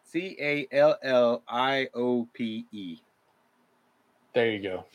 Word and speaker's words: calliope: Somebody tell me how to pronounce that. um, let's see. Um, let's see. Oh, it's a calliope. Calliope. – Calliope. Calliope. Yeah.